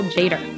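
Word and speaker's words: Tjader: So you have a T that's silent and a J, but his name is Jader. – Jazz fans already know that we're talking about Cal Jader. The T is silent.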